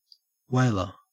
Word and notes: whaler: Australian English

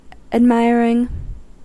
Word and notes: admiring: American English

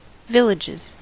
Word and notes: villages: American English

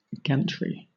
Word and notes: gantry: British English

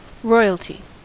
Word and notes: royalty: American English

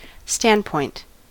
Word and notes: standpoint: American English

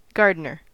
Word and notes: gardener: American English

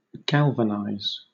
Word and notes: galvanise: British English

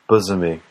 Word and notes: bosomy: American English

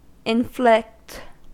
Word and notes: inflict: American English

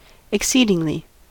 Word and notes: exceedingly: American English

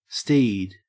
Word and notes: steed: Australian English

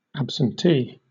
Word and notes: absentee: British English

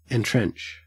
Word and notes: entrench: Australian English

